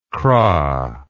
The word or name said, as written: kra